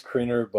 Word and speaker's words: Kriener: Do you like to go by, is it more Kriener or Kriener? – It is Kriener, but it's been butchered so many times. I just respond to both by now.